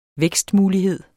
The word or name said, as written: vækstmulighed